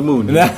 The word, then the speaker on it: Moen: Or is it M-O-E-N. – M-O-E-N. – Yeah, but it's pronounced like... Yeah, you pronounce it like moon. – I've always said Moen, so I'm glad that I know that. – It's just moon.